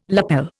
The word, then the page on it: lapel: Indian